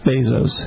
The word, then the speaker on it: Bezos: I think it's Bezos. – Bezos, B-E-Z-O-S.